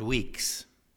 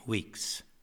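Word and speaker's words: Weekes: The place was called Weeks. It's spelled just the way it sounds, except that it has an E-S after the K, Weeks, Saskatchewan.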